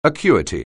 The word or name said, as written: acuity